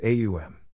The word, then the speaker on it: Aum: When you pronounce Aum, A emerges from the throat, originating in the region of the navel. U, rolls over the tongue. M, ends on the lips.